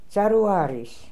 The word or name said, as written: Zarooaries